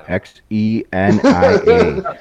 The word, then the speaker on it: Xenia: Zanya, is that how you say it? X E N I A.